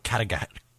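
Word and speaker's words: Cataractes: Like cataracts. – It sounds like Cataract. – Oh, Cataract. You got to roll that R.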